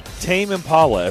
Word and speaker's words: Tame Impala: Let's see, we've got a pair of tickets to see Tame Impala at Bridgestone Arena on March 23rd. Did I say that right? – Yeah, you did.